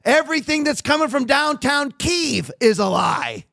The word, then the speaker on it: Kyiv: I verified with a Ukrainian friend, is that the correct pronunciation, Kyiv? And they said, yeah, it was. I was wrong. I hate that because it's been Kiev for my entire life, and now everybody calls it Kyiv.